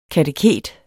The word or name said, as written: kateket